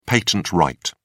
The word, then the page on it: patent right: British English